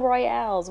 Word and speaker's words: royale: I saw those battle royales, whatever. – Yes, that is actually the proper pronunciation. A royale.